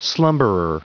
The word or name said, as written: slumberer